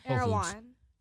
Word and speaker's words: Erewhon: We went to eat at this place called Arm One. No, I'm saying it wrong. It's like some organic like grocery store / like eatery place or whatever. – Erewhon. – There we go. Oh, okay. I said it kind of right.